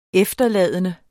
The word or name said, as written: efterladende